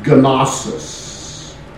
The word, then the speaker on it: gnosis: The Greek word for knowledge, if we pronounce all the letters, would be gnosis. But you can know that if you look on the sword, you don't always say all the lectors, some of them are silent, as you can know If you look in the sword, even if you have just a knife, you can know that, don't you? And so we don't pronounce the G Although Marcia and I knew a preacher who would know just enough to get himself in trouble.